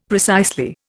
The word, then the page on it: precisely: Indian